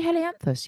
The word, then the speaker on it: Helianthus: Anyway, the scientific name for the sunflower is Helianthus. Have I pronounced that right? – I would say, yes, I'd say Helianthus, yes, or Helianthus, yep, one or the other.